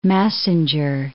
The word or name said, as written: Massinger